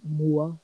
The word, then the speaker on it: MUA: I don't even think I said that right. Mua. – Moa? – You know, makeup artist? – Yeah. – Ah. – What? – Mua is makeup artist. – Oh, I did say it right.